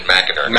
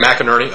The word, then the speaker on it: McInerney: Well, Jay McInerney, he pronounces it McInerney. – McInerney, okay.